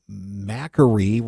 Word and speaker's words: Makary: In the news this week, the head of the FDA, his name looks like Malarkey, but it's not how you pronounce it. It's Dr. Marty Makary resigned.